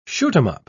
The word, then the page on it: shoot-'em-up: British English